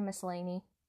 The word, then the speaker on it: Miscellany: It is written by Mercury Misencelli Annie. It's spelled Mercury Misencelli. Oh, it's Mercury Miscellany. I got it in the end.